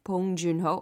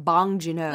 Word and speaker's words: Bong Joon-ho: and Bong Joon-ho. 어, 아니면 그 미국 사람들 발음으로 방진호.